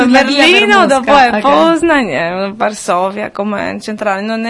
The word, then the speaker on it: Poznań: Poznań. – Dov'è? – Poznań. – Poznań.